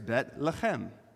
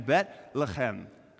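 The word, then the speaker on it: bet lechem (בית לחם): Now, the second word you might learn from Dr. Monson is pronounced lechem, lechem. And so if you put the two together, it's bet lechem. And I thought we should practice that today. So let's practice saying bet lechem, bet lechem, bet lechem.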